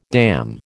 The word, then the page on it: damn: American English